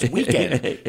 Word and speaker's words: Weeknd: How to pronounce Weekend. W-E-E-K-N-D. – Correct.